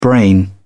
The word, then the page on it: brain: British English